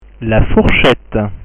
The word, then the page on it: la fourchette: French